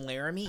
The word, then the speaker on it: Laramie: Have you met my buddy Jeremy from Laramie? You got to do all three syllables.